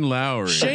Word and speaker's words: Lowry: And his last name rhymes with Owry. – Something Lowry? – Shane Lowry.